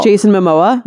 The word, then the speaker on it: Jason Momoa: Jason Momoa? – Yeah.